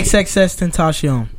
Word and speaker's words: XXXTentacion: How do you say his name? – XXXTentacion.